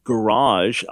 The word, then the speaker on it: garage: That we have garage, a hard G, G, and a soft G, J, in the same word.